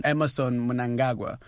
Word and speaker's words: Emmerson Mnangagwa: Emmerson Mnangagwa, a man that many people find so hard to pronounce the name. It's not as easy on the tongue as Mugabe was. – But you did it well, the best I've had so far.